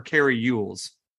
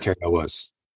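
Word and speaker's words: Cary Elwes: Is it pronounced Carrie Elwes or Carrie Ewells? Because it's Scottish. – British. Yeah, I've always pronounced it as Carrie Elwes.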